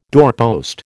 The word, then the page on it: doorpost: American English